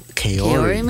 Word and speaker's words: Kaori: How do you pronounce this name? K-A-O-R-I. K-A-O-R-I. – Kaori? – Kaori? – Kaori, maybe? – Kaori. – Kaori. – Ooh, I like that. Kaori.